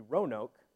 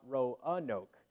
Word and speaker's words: Roanoke: They say it's supposed to be two syllables. It's supposed to be Roanoke, not Roanoke.